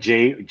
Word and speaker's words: Zhang: Jalei Jiang. – John, like Zhang.